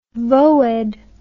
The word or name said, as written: voed